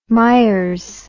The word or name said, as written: myers